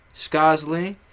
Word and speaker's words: Skozzlee: And the pronunciation, Skozzlee. That's it. It's not Skozzle, not Skozzlee, not Skozzlee. It's just Skozzlee. And if you can't say that, just say Skozz.